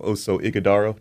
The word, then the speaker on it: Ighodaro: Yeah, well, first of all, good morning, and it's great to have Oso Ighodaro. Did I pronounce that right? – Yes, sir. Yeah,